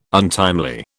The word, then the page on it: untimely: British English